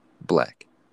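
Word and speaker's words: 6lack: Oh, and then also Six Lack, or Black, since it's spelled with a six, but it's Black.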